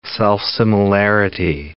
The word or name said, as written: self-similarity